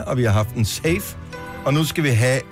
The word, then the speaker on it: Saif: Safe. – Safe. – Yes. – Safe. – S-A-I-F, korrekt? – S-A-I-F, men udtales der.